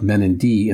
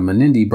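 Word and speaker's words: Menindee: My pronunciation of Menindi in the Burke and Wills episodes instead of Menindi brought me torrents of comments, most of them good-natured.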